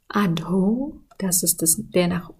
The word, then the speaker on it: Adho: Zuerst mal mit Adho. Da haben wir ein H hinter dem D. Das heißt, wir haben ein D-H, das wir aussprechen müssen. Und es kommt dann zum Adho. Und das O wie auch das E sprechen wir immer lang aus. Und zusammen ist es dann Adho. Das ist der erste Teil.